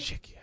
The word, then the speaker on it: Czechia: Czechia. That's how it's said on the website there.